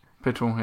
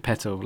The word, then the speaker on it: Pitol: Petol, yeah. P-I-T-O-L. – And you pronounce it Petal, like a flower. Or a petal. – Like a flower, yeah.